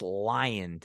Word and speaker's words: Lyon: But that makes way for Alex Lyon to enter the game. And I wrote in the notes here, say it like the bare naked ladies.